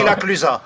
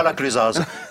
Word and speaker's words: La Clusaz: On dit la Clusa, et pas la Clusaz.